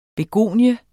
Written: begonie

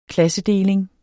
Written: klassedeling

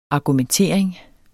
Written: argumentering

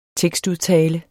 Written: tekstudtale

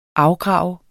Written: afgrave

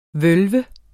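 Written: VØLVE

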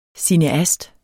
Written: cineast

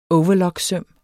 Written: overlocksøm

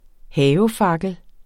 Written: havefakkel

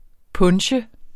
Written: punche

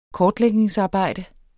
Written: kortlægningsarbejde

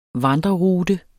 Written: vandrerute

